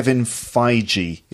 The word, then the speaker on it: Feige: Okay, let's talk about Kevin Feige. Is that how you say? Feige? Is that yeah. how you say his name? – Yeah.